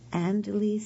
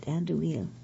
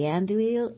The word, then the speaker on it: andouille: Oh, and 2 tablespoons of sriracha chili sauce, 2 12-ounce packages of Andalese sausage links. I did not pronounce that right. Andouille, I guess it is sausage links.